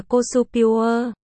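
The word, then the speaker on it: cosupure (コスプレ): Được phát âm là cosupure.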